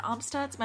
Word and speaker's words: Amstutz: You can find me everywhere at Sandra Amstutz.